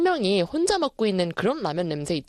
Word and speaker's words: 라면: 라면. 너무 이렇게 할 필요 없어요. 너무 라면을 굴려서 발음하지 마시고요. 라면. 이렇게 똑부러지게 발음을 하셔야 됩니다.